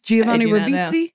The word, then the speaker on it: Giovanni Ribisi: Did I say his name right? Yes. Giovanni Ribisi.